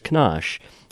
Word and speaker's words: Kenosha: Is it Kenosha? Is that how you pronounce it? – Yes, that's right. – Yeah, okay. When I drive through there, Kenosha. It's Kenosha.